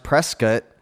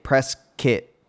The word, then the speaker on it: Prescott: Just a note regarding last episode, Prescott is pronounced Prescott, or rather say the word bike kit, press kit, press kit. Press kit, press kit. Now you got it.